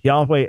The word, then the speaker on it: Yahweh: But again, my understanding, the original pronunciation would have been Yahweh as best as we understand, but cannot be dogmatic about it.